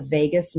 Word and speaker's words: vagus: There is actually a nerve called the vagus nerve, not Vegas like Las Vegas, but it's spelled V-A-G-U-S.